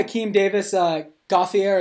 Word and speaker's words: Davis-Gaither: I had Akeem Davis Gaither, is that how you pronounce it? As my fifth Gaither? Okay, cool.